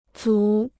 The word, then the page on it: 组: zǔ